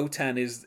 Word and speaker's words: OTAN: But OTAN is how you say the French version of NATO.